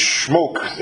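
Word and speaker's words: Shmok: It's spelled S-H-M-O-K. I know smoke, but it's schmoke in how you have it titled.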